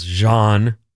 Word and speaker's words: genre: It's two syllables, genre.